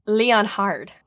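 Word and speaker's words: Leonhard: Well, this one, it's like, it's spelled with an H. It's like Leon Hard, which is fun.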